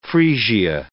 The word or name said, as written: freesia